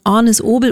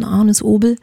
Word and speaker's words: Agnes Obel: November, das wäre der Termin für alle Ornis Obel oder auch Agnes Obel Freunde, aber sie selber hat uns ja nochmal erzählt, sie wird eigentlich Ornis ausgesprochen, Ornis Obel.